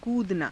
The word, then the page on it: kuudanaa: Hindi